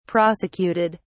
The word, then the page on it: prosecuted: British English